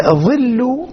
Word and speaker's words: ظل: ظل أو ظلة تنطق بالضم وتنطق بالفتح.